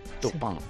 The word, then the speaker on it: Turpin: ¿Cómo se pronuncia? Escrito Turpin. ¿Cómo Turpin se pronuncia en francés? – Turpin. – Turpin. Tupán. Pues ya lo sabes, Miguelito.